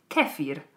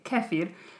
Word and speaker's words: kefir: I started pronouncing it the British way. Kefir. The accent is on the... Yeah, kefir.